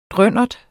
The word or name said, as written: drønnert